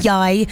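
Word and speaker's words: Yaii: An artist called Yai, so Y-A-double-I is how you spell it.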